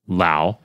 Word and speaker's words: Lou: But anyway, this particular review, a user by the name of their name is spelled like Lou, L-O-U, but then it says rhymes with wow. So I guess it's Lau.